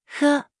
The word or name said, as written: 喝